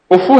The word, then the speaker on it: کفو: کیوں یہ لفظ عربی کا یہی ہے, کفو ہے عربی کا تلفظ, ٹھیک ہے.